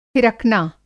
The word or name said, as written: फिरकना